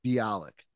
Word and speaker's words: Bialik: I think Bialik. Bialik.